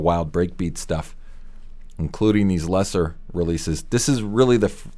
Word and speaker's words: Lesser: This release was spelled just LSR, but still pronounced Lesser.